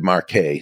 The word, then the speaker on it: Marquet: His book, Turn the Ship Around, Captain David Marquet, spelled like Marquette, but Marquet.